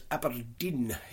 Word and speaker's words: abrdn: The funniest comment of the week was when we had somebody was reporting on the job losses at Aberdeen. You know, A-B-R-D-N, they spell it now. Aberdeen. I always have to say it in a Scottish accent.